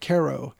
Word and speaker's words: Cairo: it's spelled Cairo, like Cairo, Egypt, but they pronounce it Cairo. Cairo, Illinois.